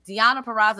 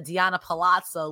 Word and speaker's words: Purrazzo: First of all, she pronounced her name correctly. Deonna Purrazzo instead of Deonna Palazzo, which hilarious.